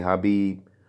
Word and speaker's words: Khabib: We pronounce our K's, my boy. We don't really do the silent K's unless we talking about a knife. So you got to be Khabib on this podcast. I've been hearing a lot of people say Khabib.